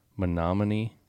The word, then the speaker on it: Menominee: but Menominee.